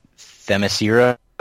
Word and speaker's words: Themyscira: What's the name of the island? Themyscira or something? – Yes, it's like Themyscira or something. – There you go. That's probably a better pronunciation than mine.